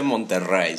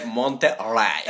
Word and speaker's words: Monterrey: allá, es que en inglés no, no existen las palabras con doble R. Entonces, se leía. O sea, llegó aquí como la chica del TikTok. O sea, no se dice Monterrey, se dice Monterrey.